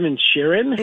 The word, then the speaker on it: Inisherin: I'm recognizing, like the Banshees of, how do you say that name? Inisherin. – Yeah, Banshees of Inisherin.